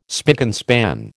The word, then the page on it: spick and span: American English